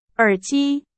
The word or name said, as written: 耳机